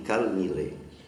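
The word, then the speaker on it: Kol Nidre: Et si c'était de l'hébreu, on dirait kol hanedarim. En araméen, on dit kal nidre. Avec un kamat, mais ça se prononce kal.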